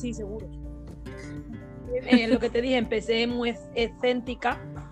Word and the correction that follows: escéptica: se dice escéntica, ¿no? no, sé si lo he dicho bien. – Escéptica. Exacto.